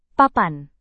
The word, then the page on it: papan: Indonesian